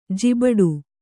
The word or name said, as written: ಜಿಬಟು